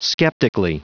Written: skeptically

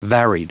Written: varied

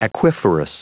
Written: aquiferous

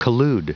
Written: collude

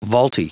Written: vaulty

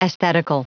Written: aesthetical

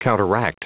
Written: counteract

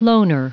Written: loner

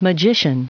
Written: magician

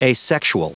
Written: asexual

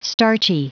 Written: starchy

